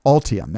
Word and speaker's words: Ultium: Ultium, that's how it's pronounced.